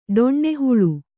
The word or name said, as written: ಡೊಣ್ಣೆಹುಳು